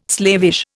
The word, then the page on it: slavish: Indian